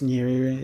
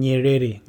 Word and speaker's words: Nyerere: Julius Nyerere is the name. – How do you pronounce the last? How do you say the last name? – Nyerere. – Nyerere. Julius Nyerere.